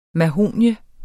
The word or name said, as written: mahonie